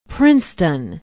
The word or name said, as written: princeton